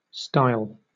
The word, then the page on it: stile: British English